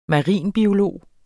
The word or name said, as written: marinbiolog